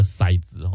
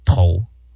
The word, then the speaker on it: 骰子: 阿卡利公齁，你剩下几把刀啊，那个骰子齁，它是念骰子吗？还是它有其他读音？阿卡利蒙，你有没有听过其他读音？在这个国语词典齁，他们把这个正确的读音不叫骰，叫做头。我们一个头的头，头子，但是语音啊，语音讲骰是 OK 的，哇，我跟你讲啊，所以我们现在如果回去考这个小学这国语啊，说不定都不会及格的哦。